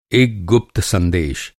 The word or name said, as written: गुप्त